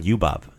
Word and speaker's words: ljubav: So we have ljut. That's the LJ sound. The same word as in ljubav. Same pronunciation.